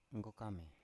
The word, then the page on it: nggokame: Biak